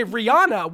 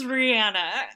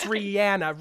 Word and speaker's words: Rihanna: I know we all say Rihanna. Why? How did that happen? How did we all start saying this woman's name wrong? – No idea, but it's been going on for like 2 decades. Like, what the hell? But yes, it is Rihanna.